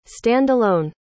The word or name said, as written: stand-alone